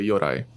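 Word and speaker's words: yūrei: In Japanese culture, it's actually pronounced or called a yorai,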